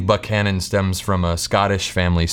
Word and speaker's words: Buckhannon: First, it's Buckhannon, pronounced with a hard K, not to be confused with Buchanan, a much different city in a much different state.